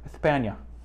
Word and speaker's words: España: And last but not least, I'll even say it how they pronounce it so we're not offensive, because you got to do like the lisp. España.